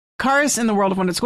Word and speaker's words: Charis: It looks like Kiara. – Karis. – Karis. It's not spelled Karis. It's spelled C-H-A-R-I-A-S. – C-H-A-R-I-S. – I-S.